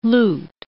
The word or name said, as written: loot